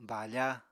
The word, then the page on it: bafa: Apatani